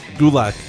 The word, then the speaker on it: Gulak: Gulak. Gulak. – Gulak. I went on his Twitter and it says it's pronounced like attack.